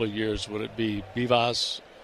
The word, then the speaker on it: Vivas: So Bivas, who spells his last name V-I-V-A-S, but he pronounces it Bivas, is now 2 for 2 in this game, and that's 5 hits for the Nationals.